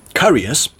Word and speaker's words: caries: caries, it's called caries. I just looked up the pronunciation because I wasn't sure.